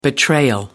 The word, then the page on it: betrayal: British English